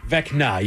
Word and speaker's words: Vecna: Right now, the CEO of Netflix is like, quick, throw Venka in Bridgerton. – Did I mispronounce it again? – Vecna, you went Venka.